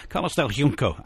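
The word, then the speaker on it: Carlos del Junco: He's from Cuba, and therefore it's in Spanish, but therefore probably not as Spanish would pronounce it, but more as the Cubans would pronounce it. Carlos del Yunco. Yunco.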